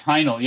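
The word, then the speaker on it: Haenel: Yeah, it's Heino. Yeah, it's not. It's don't try to overdo it. It's yeah. – It's like it. Remember, German is just like it's everything is pronounced in German.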